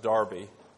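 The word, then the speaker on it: Derby: I do want someone to explain to me at some point why it's pronounced Darby.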